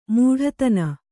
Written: ಮೂಢತನ

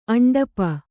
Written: ಅಂಡಪ

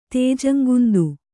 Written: ತೇಜಂಗುಂದು

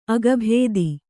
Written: ಅಗಭೇದಿ

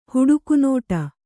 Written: ಹುಡುಕುನೋಟ

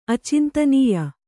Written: ಅಚಿಂತನೀಯ

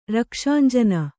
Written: ರಕ್ಷಾಂಜನ

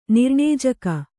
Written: ನಿರ್ಣೇಜಕ

